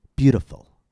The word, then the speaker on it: beautiful: Stress is on the first syllable, bu. Listen and hear the long vowel and the higher pitch. Beautiful. Beautiful. Now let's find where the T falls in relation to the stress. In this word, T comes after the vowel sound U and before the unstressed vowel I, and we therefore use the tap.